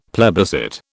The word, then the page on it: plebiscite: British English